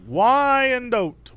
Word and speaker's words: Wyandotte: how do I say that? – Wyandotte? Oh. Uh-huh. – Wyandotte. Why? why? – Wyandotte. – Oh, it's the name of a of a place. – Wyandotte, Michigan. – Yeah. All right. So, but it's called Smuggler's Run. – Oh, I see. It's pronounced Wyandotte. – Wyandotte. That's it.